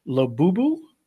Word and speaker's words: Labubu: Labubu? L-A-B-U-B-U. – I think you're saying it right, yeah.